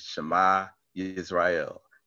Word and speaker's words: Shema Yisrael: And this is how you pronounce it in English, Shema Yisrael.